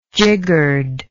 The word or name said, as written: jiggered